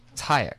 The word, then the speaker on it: Tyack: Tyek. – Oh, Tyek. – Tyek. – How do you spell that? – T-Y-A-C-K.